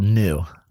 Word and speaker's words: Nue: But we stumbled upon a restaurant on Capitol Hill called New. – Oh, I've heard of this place. – It is spelled N-U-E.